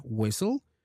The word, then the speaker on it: whistle: And to finish with this segment, the T, the silent T, one pattern that we could find for the silent T occurs when it is paired with the letter L in words like whistle, bristle, thistle, bustle, hustle, and castle.